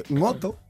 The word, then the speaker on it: Ngoto: Y hay una cosa muy graciosa, y es que Ngoto, yo lo pronuncio así porque sé pronunciar mi nombre. Entonces, pero resulta que en España no existe ese fonema, tío. No, o sea, Ngoto, el Ngo... – Es GN, es como en francés.